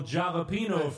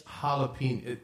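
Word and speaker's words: jalapeño: Now, this is not to be confused with the annual Jalapeno Festival. – That's jalapeno. The J's are pronounced like H's. It's jalapeno.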